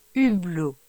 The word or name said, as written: Hublot